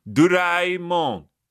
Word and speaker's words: Doraemon: Is it Doraemon or Doraemon? – Doraemon. – Ooh, okay. I gotcha. – That's how it's pronounced.